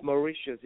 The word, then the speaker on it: Mauritius: Is it Mauritius? Is that how you say it? – It's Mauritius.